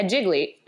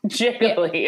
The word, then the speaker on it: Gigli: It's spelled G-I-G-L-I. – Yeah, Jiggly. – Jiggly yeah.